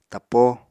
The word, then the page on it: tappo: Apatani